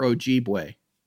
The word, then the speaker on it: Ojibwe: I've always wondered, it is Ojibwe or Ojibwe? – Ojibwe. – Like it's spelled.